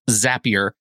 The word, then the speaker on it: Zapier: And it is apparently pronounced Zapier because Zapier makes you happier, is what they say internally.